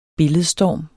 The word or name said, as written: billedstorm